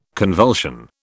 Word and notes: convulsion: British English

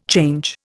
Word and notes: change: Indian

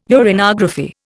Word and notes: uranography: Indian

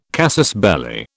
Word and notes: casus belli: British English

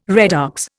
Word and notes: redox: Indian

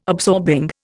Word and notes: absorbing: Indian

